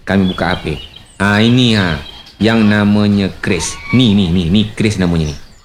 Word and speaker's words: QRIS: Keris, Bu. Keris. Q-R-I-S. Dibacanya keris, bukan keris.